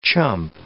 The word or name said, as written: chump